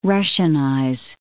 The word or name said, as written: Russianize